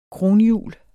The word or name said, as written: kronhjul